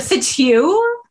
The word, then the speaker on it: Fitzhugh: Or is it Fitzhugh? – Fitzhugh. It's probably Fitzhugh. Yeah. I was giving it the silent H in there, but no, yeah. I think probably Fitzhugh is more appropriate than Fitza.